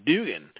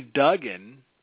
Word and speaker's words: Duggan: They called him Dugan. And we just thought that was absolutely hilarious, that it's clearly Duggan.